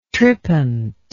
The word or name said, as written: trippant